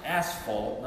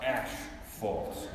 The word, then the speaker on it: asphalt: When you find out that it really is pronounced asphalt, not ashfault, like you've been saying.